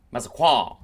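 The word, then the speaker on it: Massaquoi: Massaqua.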